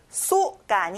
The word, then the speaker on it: so: 그래서 그러므로 so 친구들 so가 아니에요. o 발음을 내야 돼요.